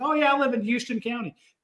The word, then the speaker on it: Houston: Wait, no, it's pronounced Houston County, don't you know? – No.